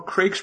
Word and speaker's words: Craig: I say it at the start of the show, if you don't know Craig's pronounced, Craig.